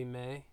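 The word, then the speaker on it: Me: Gonna be May. – Yeah, that's how they say it.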